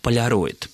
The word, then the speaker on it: поляроид: Слово «полароид», все-таки произнесу так, хотя написано «поляроид», существует в нашем языке с довоенных времен. Оно в соответствии с правилами словообразования, считает Борис Васильевич, и по аналогии с словами «полярный», «полярник», «полюс» писалось и произносилось только как «поляроид».